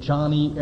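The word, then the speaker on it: Joni: It's J-O-N-I, but she pronounces it Johnny Erickson Tada.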